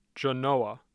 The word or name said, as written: Genoa